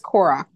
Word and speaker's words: Korok: I keep seeing people torturing the little Kokora seeds. Kokora. Am I saying that right? Yes. – Yeah. – I thought it was Korok, but I could. – Korok. There we go. I like Korok better.